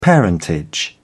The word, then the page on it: parentage: British English